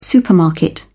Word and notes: supermarket: British English

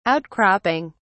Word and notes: outcropping: British English

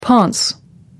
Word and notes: ponce: American English

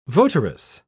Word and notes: votaress: British English

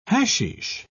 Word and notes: hasheesh: British English

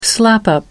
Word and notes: slap-up: American English

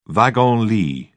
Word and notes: wagon-lit: British English

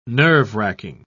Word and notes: nerve-wracking: British English

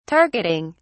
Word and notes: targeting: British English

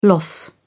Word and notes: loss: British English

